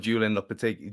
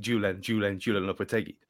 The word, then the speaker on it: Julen Lopetegui: And that chant that we shared on Twitter the other day, you have to listen really, really closely to hear the, because it does sound like Julen Lopetegui, Julen Lopetegui. That's what it sounds like, but it is very much the silent H.